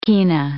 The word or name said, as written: kina